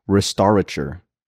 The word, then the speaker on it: restaurateur: Restaurator? Okay. – This is the actual pronunciation. Restaurateur.